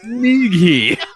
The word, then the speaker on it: Nighy: Nighy. – I believe the G is silent.